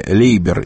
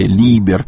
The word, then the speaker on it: Лейбер: Умер, к сожалению, Джерри Лейбер, или Либер, по-разному по-русски произносится его фамилия.